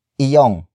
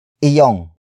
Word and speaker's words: iyo: But before that, we have iyong. Iyong. Iyong. Iyong is spelled as I-Y-O-N-G.